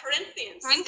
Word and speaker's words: Corinthians: I'm not going to be able to say the name because I'm so bad with pronunciation, but is it Corinthians 13? – Corinthians.